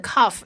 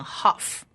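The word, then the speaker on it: kaf (כף): 这里的 kaf 因为它中间有一个黑点，所以它念 kaf。如果中间没有一个黑点就念 haf。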